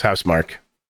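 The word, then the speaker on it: Housemarque: Yeah, I always thought it was House Marquee, but it's House Mark.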